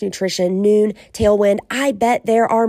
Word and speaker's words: Nuun: I also like Noon. Those are those tablets. It's spelled N-U-U-N, and they have hydration electrolyte tablets.